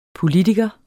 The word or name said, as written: politiker